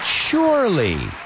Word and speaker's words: surely: Slowly, but surely. Poco a poco. Slowly. But surely, right? Slowly, but surely. Y fijaos, ese sure, S-U-R-E-L-Y, se pronuncia como si fuera una H ahí también.